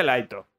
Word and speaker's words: Light: va a topar un chico muy inteligente llamado Laito. Sí, o Light. O Light. Pero bueno, se pronuncia Laito en japonés.